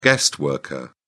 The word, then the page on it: guest worker: British English